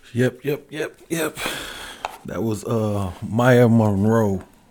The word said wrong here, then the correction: Mereaux: Moreau. I'm gonna spell it for y'all, man. It's uh M A Y A M E R E A U X.